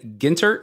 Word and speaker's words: Gintert: So the voice that you're hearing from the ether is our guest tonight, Jason Gintert.